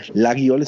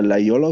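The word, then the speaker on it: Laguiole: Ich habe auch so ein paar Laiol, das wird, ne, viele sagen Lagiol, es wird aber Laiol ausgesprochen. Die haben auch tolle Messer, aber wie gesagt, dann haben die da so 12C-Stahl, also wirklich so günstiges Material.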